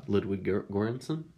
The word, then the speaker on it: Ludwig Göransson: Ludwig Göransson? – I wasn't even trying to pronounce it, but you can. Yeah, yeah, good job. You got it.